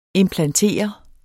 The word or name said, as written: implantere